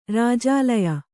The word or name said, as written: ರಾಜಾಲಯ